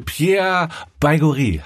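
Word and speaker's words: Pierre Baigorry: Hier ist Sanft und Sorgfältig mit Bömi Böhmermann und Olli Schulz und unserem Gast heute Pierre Baigori.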